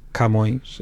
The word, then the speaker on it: Camões: Camões, também deveria ser Camões, e é Camões, ou seja, a sílaba tónica é o mões, e nós abrimos o K.